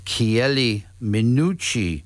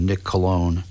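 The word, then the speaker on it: Chieli Minucci: And started out with Chiele Minucci, the most mispronounced man in contemporary jazz. Chiele. Although I heard an announcer one time say, introduce a Nick Colon.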